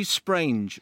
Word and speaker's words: Sprange: Matthew Sprange, rhymes with strange.